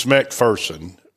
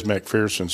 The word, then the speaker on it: McPherson: When I say your name, I feel like I'm mispronouncing it, but I say McPherson, but you say McPherson. well, uh, it's McPherson, but everybody says McPherson, so we just let it go.